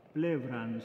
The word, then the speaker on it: pleura: Plevran, se dice eso en griego, pleura se escribe.